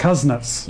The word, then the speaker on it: Kuznets: Kuznets.